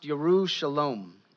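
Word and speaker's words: Yerushalom (ירושלים): Jerusalem is pronounced Yerushalom. It means the city of peace.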